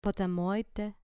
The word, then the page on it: potamoi te: grec